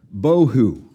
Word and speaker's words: bohu: Tohu, bohu. Like they rhyme with each other, right? T-O-H-U, B-O-H-U. Tohu and bohu.